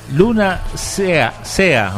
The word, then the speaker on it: Luna Sea: Luna Sea, o sea, mar. ¿Que lo está buscando? – ¿Cómo se escribe? – Luna. – S-E-A.